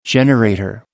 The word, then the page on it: generator: American English